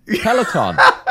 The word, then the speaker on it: Peloton: How do you pronounce Peloton? – There's no E on the end. Peloton.